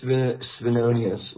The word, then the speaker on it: Svenonius: It's Svenonius, like Svengali.